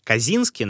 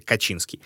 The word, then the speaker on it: Качинский: Кстати, эта фамилия иногда пишется и проговаривается как Казинский, но все же корни у нее польские, поэтому, мне кажется, будет правильнее немножко остановиться на первом варианте — Качинский.